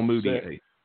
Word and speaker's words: Mudiay: Okay, and I'm reading here somebody's got that Rudy Gobert and Emmanuel Mudiay, is that how you say it? I'm not sure how you pronounce his last name. – Emmanuel Mudiay.